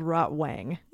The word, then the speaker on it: Rotwang: Well, you said Rotwing, but it is spelled Rotwang.